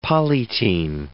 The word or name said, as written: polytene